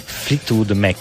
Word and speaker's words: Fleetwood Mac: No, questo, lì c'era, perché stavo parlando del Flatwork, stavo pensando ai Flatwork Mac, però lì Mick Flap c'è ancora un Flatwork, no? – Fleetwood Mac.